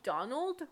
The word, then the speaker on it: Donal: It's spelled D-O-N-A-L, like Donald without the D. – Right. – And I remember mentioning it in a video, being like, by Donal Ryan. And someone was like, it's Donal.